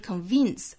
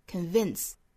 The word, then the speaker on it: convince: 那这里面是 convince 就很像咬一下嘴唇那样的动作，但是又不是真正的说咬住这个嘴唇，而是轻轻的摩擦 convince 还需要注意第二个音节，它的元音呢是一个松口的松元音的 A, 不要发成了 E, 不是 convince, 而是 convince.